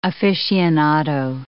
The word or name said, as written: aficionado